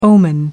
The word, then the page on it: omen: American English